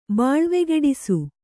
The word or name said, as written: ಬಾಳ್ವೆಗೆಡಿಸು